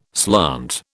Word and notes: slant: British English

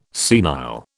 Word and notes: senile: British English

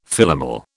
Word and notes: Philomel: British English

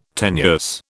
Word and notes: tenuous: British English

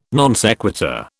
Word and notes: non sequitur: British English

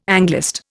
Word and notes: Anglist: Indian

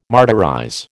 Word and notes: martyrize: American English

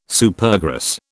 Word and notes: Supergrass: British English

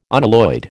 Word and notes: unalloyed: American English

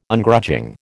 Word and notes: Ungrudging: American English

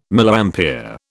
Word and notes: Milliampere: British English

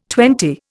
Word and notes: twenty: Indian

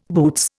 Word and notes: boots: Indian

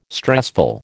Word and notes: stressful: American English

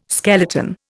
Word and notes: skeleton: Indian

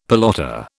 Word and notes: pelota: British English

